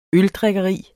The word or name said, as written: øldrikkeri